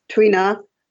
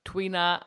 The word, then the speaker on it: Tuina: It's Tuina and Qigong. – Tuina and Qigong.